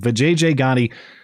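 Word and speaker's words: Vijaya Gadde: We'll catch up on some stories we have not yet had the chance to discuss surrounding that, including Twitter General Counsel Vijay Gotti. I believe that is the correct pronunciation, I'm told. Vijay Gotti cried at a meeting this week about Twitter's future under Elon Musk.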